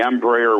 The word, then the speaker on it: Embraer: Is Embraer, is that how you pronounce it? – Yeah, that's the Embraer 135 is the airline designation.